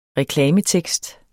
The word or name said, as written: reklametekst